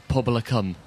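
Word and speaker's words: Pobol y Cwm: How do you say it? – Publicum. – Right, okay, same.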